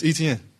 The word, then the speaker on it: Etienne: So I'm like, man, it's Etienne. Like, is how you see it is how you say it.